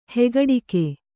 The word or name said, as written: ಹೆಗಡಿಕೆ